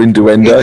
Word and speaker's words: innuendo: Here comes Dr. Innuendo. – Here comes Dr. Innuendo. It's not how you say it, isn't it? Innuendo. – Innuendo. – Innuendo. There we go. Just missing that U.